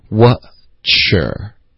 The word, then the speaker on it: What is your: 所以这个 What is your 的这三个字呢，我们一般就是在实际发音里面呢，就会发成 Watcher 就行了。